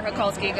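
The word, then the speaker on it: Hacholski: At Megan underscore Hokulski.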